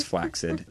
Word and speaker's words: flaccid: The proper pronunciation is flaccid.